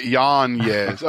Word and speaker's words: Yanez: Got it, Yanez.